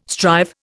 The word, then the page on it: strive: Indian